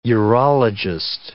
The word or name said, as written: urologist